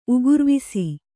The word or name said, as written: ಉಗುರ್ವಿಸಿ